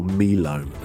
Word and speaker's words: Milo: So for me, one would be a chocolate malted powder that you use to make sort of hot drinks or cold drinks called Milo, or Milo as we pronounce it in Nigeria for some reason.